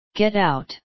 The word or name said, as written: get out